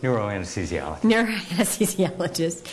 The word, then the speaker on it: neuroanesthesiologist: Neuroanesthesiologist.